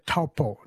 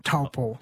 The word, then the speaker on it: Taupo: I said Taupo, but it's not Taupo. – She would say Taupo. Taupo. – Taupo. Taupo? Sorry? – Almost like a Taupo. I'm not pronouncing it fully right.